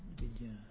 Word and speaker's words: vidya: And the Sanskrit spelling is vidya. Yeah, I'm sure it has a long A, but probably. Vidya.